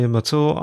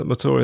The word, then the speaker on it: Mathur: My name is Roy Matur. I'm a writer. Matur is spelt M-A-T-H-U-R.